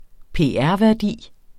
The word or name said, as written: pr.